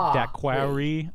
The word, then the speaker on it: daiquiri: Okay, daiquiri, let's see, D-A-I-Q-U-I-R-I? – Yes! – Okay! – Excellent! Because... most people have the tendency to put the A in for the second I. Daiquiri. – Okay. – Yeah, more like that as opposed to you.